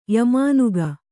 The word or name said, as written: ಯಮಾನುಗ